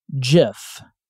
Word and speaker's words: GIF: So in honor of his memory, I am going to pronounce the file format the way he said you were supposed to. GIF.